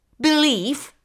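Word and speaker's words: belief: Believe versus to believe, substantivul convingere, credință sau părere, este ortografiat și pronunțat la final cu litera F, în timp ce V-ul intervocalic al verbului corespondent a crede, a considera, a socoti ori a fi de părere se pronunță ca atare. Believe. Warmth, substantivul cu înțelesurile propriu și figurat de căldură. Warmth, ortografiat W-A-R-M-T-H, derivă de la adjectivul warm, cald sau călduros.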